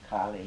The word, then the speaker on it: Kali: Round its center of the lotus, round its center, white ali, A-L-I, long A. Clockwise. Then red Kali, long A, K-A-L-I, counterclockwise, then the heart of dependent arising, blue and I recite them in snake-like succession.